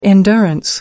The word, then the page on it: endurance: American English